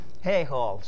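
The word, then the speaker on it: Haigh Hall: as in H-A-Y, you might not actually have found it, because it's actually Haig Hall, H-A-I-G-H, Haig Hall, but pronounced for some reason Hay Hall.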